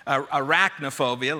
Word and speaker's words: arachnophobia: Most of you have heard of this one, anacrophobia, arachnophobia, let me say that correctly, that's the fear of spiders.